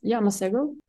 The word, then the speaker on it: Masego: Masego. That's how I pronounce it, at least.